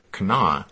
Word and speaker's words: Kanawha: And as near as we can tell, there in West Virginia, despite how it's spelled, they seem to just pronounce it Kanawha.